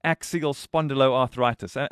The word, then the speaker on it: axial spondyloarthritis: And that makes me realize I don't know, and maybe the others listening also don't know enough about axial spondyloarthritis. Am I on the right track with my pronunciation there? – Brad, you're spot on.